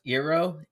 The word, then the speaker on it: Eero: But his name is, I think it's pronounced Eero. It's E-E-R-O, double E's. – It's like Eero.